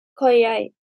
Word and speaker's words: KOIAI: KOIAI.